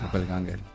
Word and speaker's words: Doppelganger: Doppelganger. – Doppelganger, sí.